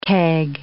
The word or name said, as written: keg